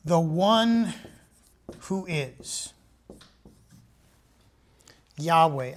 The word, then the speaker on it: Yahweh: Since the writing, original writing of the King James in 1611, older copies of the Bible have come out, and a better understanding of how to pronounce this original word, which we have realized later is YHWEH, which is Yahweh.